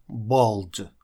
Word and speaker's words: bald: Ну а если волос, к сожалению, совсем нет, то вы лысый, и лысый это bald, bald. Обратите внимание, что буква A перед L здесь читается звуком O, bald.